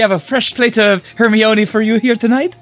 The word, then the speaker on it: Hermione: Hermione? – Okay, that technically counts because it is how it's written. – That's how I always pronounced it until I watched the first movie.